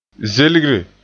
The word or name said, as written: zelgre